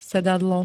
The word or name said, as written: sedadlo